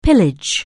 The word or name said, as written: pillage